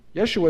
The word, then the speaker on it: Yeshua: Yeshua is how you say it in Aramaic and Hebrew.